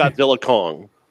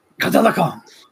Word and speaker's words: Godzilla x Kong: The X in Godzilla X Kong is silent, just so you know. It's just okay. Godzilla Kong. – Godzilla Kong.